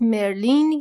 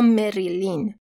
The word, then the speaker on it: Marilyn: مدل آمریکاییش هست مرلین یا گاهی اوقات حتی مریلین که گفتنش راستش یکم برای من سخته، و مدل بریتانیاییش هست مرلین.